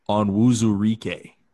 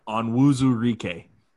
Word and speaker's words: Onwuzurike: For anybody who's still sitting here listening to us try to fumble through this, it's Levi Onwuzurike. – That's how you say it. Washington defensive tackle. – Yeah. – Yes. Yeah. – yeah. – Onwuzurike, just like it, just like it's spelled.